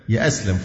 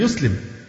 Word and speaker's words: يسلم: يأسلم فلان عايز يأسلم، هي الفعل يسلم، إنما يأسلم دي كلمة من اختراع الناس في هذا العصر من الركاكة وضعف اللغة الشديد،